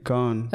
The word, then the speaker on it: Gane: Ngannou and Gane. – Close. Cyril Gane. Gone.